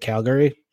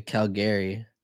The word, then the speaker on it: Calgary: Oh, you say it like Cal. Like, oh, I was going to say. – Oh, so like, it's Cal? – It's Calgary. Yeah.